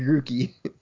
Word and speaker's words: Grookey: It's going to include three new starting Pokemon, which are going to be Scorbunny, Sobble, and Grookey. – Are we pronouncing those right? – Yes, you are so far. – Okay, Scorbunny, Sobble, and Grookey.